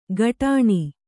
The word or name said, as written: ಕಡಾಣಿ